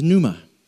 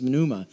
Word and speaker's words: pneuma: And the Greek, that was the Hebrew, so the Greek equivalent is pneuma. I almost said pneuma. I know, because that's how it's spelled.